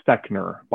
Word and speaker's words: Fechner: i believe our last name is properly pronounced Fechner by all indications. So my mom says Fechner. Uh, other members of the family had said Fechner.